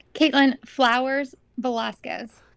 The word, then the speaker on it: Katelyn Flowers-Velasquez: Caitlin Flowers Velasquez.